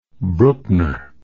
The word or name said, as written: brookner